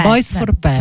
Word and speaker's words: Boys for Pearl: Boys for Pearl.